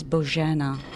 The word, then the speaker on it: Bojana: It is Bojana. – Bojana. Okay, see? I did not say it right, did I, when I said, saw you? – It's hard, because G does not exist in English, English alphabet.